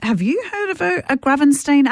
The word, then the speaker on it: Gravenstein: So we'll Gravenstein, is that how you pronounce it? – Yes.